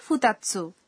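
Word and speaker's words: ফুতাৎসু (ふたつ): আচ্ছা, এর আগের পাঠে আমরা শিখেছিলাম দুই সংখ্যাটিকে জাপানি ভাষায় বলা হয় নি, অথচ এখানে বলা হচ্ছে ফুতাৎসু, এর কারণটা কি? – সংখ্যাবাচক শব্দগুলোর পরে যদি ৎসু যুক্ত হয় তাহলে এক থেকে দশ পর্যন্ত সংখ্যাগুলোর উচ্চারণ পাল্টে যায়।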